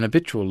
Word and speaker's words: habitual: By contrast, an habitual liar is commonly said with a silent H, although it would be odd not to sound the H in habit.